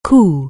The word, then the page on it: coo: anglais